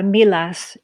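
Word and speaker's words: Millas: Milas. They do pronounce the L in this case. Milas.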